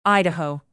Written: idaho

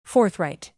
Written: forthright

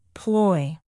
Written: ploy

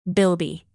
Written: bilby

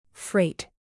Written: freight

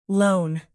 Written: lone

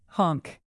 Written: honk

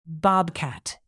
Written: bobcat

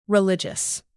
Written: religious

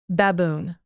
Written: baboon

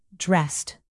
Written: dressed

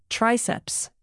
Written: triceps

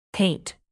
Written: paint

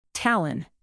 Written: Tallinn

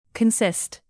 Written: consist

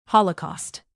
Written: holocaust

